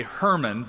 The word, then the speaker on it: Hermon: The one that I think fits the description the best is a little further north called Mount Hermon, H-E-R-M-O-N. We say Hermon, but that reminds me of Herman Munster. So the Hebrew pronunciation would be Hermon, Mount Hermon.